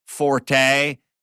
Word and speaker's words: forte: But when we did Celebrity Apprentice and some group, I don't know which team, called themselves Forte, he kept telling everyone it's pronounced Fort.